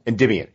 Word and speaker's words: Endymion: Endymion.